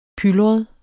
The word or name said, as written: pylret